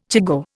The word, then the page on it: chigoe: Indian